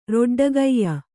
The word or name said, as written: ರೊಡ್ಡಗೈಯ